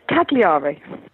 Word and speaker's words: Cagliari: Mark says, what's a good tour for Cagliari? Did I pronounce that right? – Indeed, Cagliari.